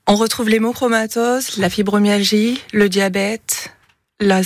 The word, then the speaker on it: hémochromatose: Et alors cette soirée dont on parlait se déroule durant la semaine mondiale de l'hémochromatose, je l'ai bien prononcé. – C'est bien ça.